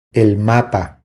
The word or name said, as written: El mapa